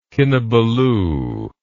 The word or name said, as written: kinabalu